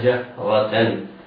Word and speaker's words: جهرة: جهرة. جهرة الجيم والهاء مرققتان، الراء مفتوحة مفخمة. كلمة جهرة آخرها تاء مربوطة، توصل تاء ويوقف عليها بالهاء. اسمع: حتى نرى الله جهرة فأخذتكم.